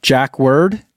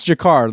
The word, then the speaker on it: Jacquard: Super just says the word is pronounced Jack Word. Jacquard? No, I don't think so. I think it's Jacquard, like Jean-Luc, the captain. Rhymes with Picard.